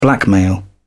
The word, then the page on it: blackmail: British English